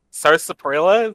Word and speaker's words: sarsaparilla: I made a joke once that she she thought uh, s- sa- sar- sarsaparilla. Is that how you say it? Root beer? – Sarsaparilla.